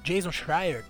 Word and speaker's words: Jason Schreier: O Jason Schreier. Eu não sei pronunciar o. – Você fez certinho, dele.